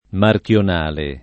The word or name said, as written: marchionale